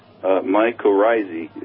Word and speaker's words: mycorrhizae: Now, it's a kind of a hard word to say, but mycorrhizae is how it's pronounced.